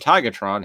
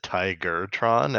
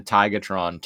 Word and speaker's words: Tigatron: And then the voice actor for Optimus said Tigertron, and I was like, hey, you say his name right. – He always says Tigertron.